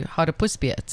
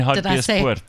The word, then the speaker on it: Hartbeespoort: Well, I was going to Hartbeespoort, so it was... – You mean Hartbeespoort? – I said it wrong, sorry.